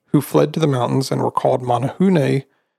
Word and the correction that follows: Menehune: um, or sorry, Menehune.